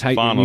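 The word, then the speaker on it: Phaunos: It's Fanos. – Fanos.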